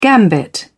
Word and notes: gambit: British English

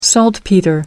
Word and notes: saltpetre: American English

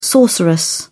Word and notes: sorceress: British English

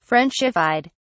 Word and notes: frenchified: British English

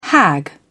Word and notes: hag: British English

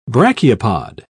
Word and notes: brachiopod: British English

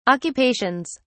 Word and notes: occupations: British English

